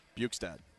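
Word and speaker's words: Bjugstad: Cats traded Nick Bukestad and Jaron McCann. Did I say that wrong? – Bukestad.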